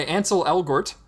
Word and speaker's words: Ansel Elgort: Played by Ansel Elgort, who I'm sure I'm mispronouncing his name. – No, you got it, man.